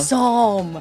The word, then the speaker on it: psalm: Sorry, did I not pronounce that right? Psalm. – It's a psalm.